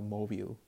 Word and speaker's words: móvil: Remember we changed el teléfono celular to el móvil, M-O-V-I-L, with an accent on the O, okay?